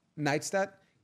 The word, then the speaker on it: Neistat: How is that pronounced right? – Neistat. – Casey Neistat.